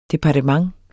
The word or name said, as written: DEPARTEMENTET